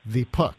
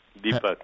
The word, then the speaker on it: Deepak: no, you say his name, Deepak. – Deepak, yes. – Say it right.